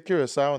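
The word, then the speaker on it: Curaçao: Say Curacao now. – Did I say it wrong? – No, you said it right.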